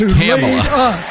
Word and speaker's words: Kamala: So it's Kamala.